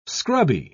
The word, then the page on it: scrubby: British English